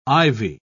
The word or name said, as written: Ivy